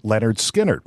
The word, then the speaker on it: Lynyrd Skynyrd: How do you pronounce that name? Well, MCA Records had to tell the press and the public that it was Lynyrd Skynyrd.